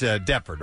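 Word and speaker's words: Deptford: You think it's Deffert, right? – Deffert, yeah. – Deffert, New Jersey. – Yeah. – It's just how they say it.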